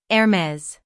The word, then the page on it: Hermès: /ɛrˈmɛz/; American English